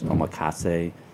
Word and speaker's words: omakase: And we're having a 15-course omakase. I looked out how to pronounce that. – You did good. – Did I get that? Yeah. Omakase.